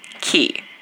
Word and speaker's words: quay: Also, listen, I love you, John Cusack, but Q-U-A-Y is pronounced key. – Wait, Q-U-A-Y? – Is key.